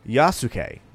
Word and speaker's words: Yasuke: His name is Yasuke. Now, I looked up that pronunciation to make sure I got it right, because it's important.